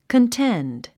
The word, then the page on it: contend: kənténd; カンテンド